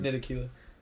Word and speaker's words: Ntilikina: Frank, I don't even know how to pronounce his name. – Ntilikina.